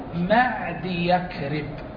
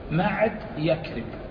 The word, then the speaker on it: معدي كرب: نعم هنا فائدة، معدي كرب، يختلف الناس في نطق هذه الكلمة، هل الصواب أن تقول معدي كرب أو معدي يكرب؟ يعني تضع الياء بجوار الدال أو تشبك الياء بالكاف، معد يكرب، هذا لنطقان مشهوران عند الناس، ولكن الصحيح والصواب أن الياء ملحقة بالدال، هذا يسميه العلماء مركب مزجي، هذا مركب مزجي، فيعني معدي كرب وليس معدي يكرب،